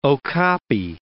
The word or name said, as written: okapi